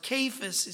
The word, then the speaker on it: Cephas: And that he was seen of Cephas, or Cephas is sometimes pronounced.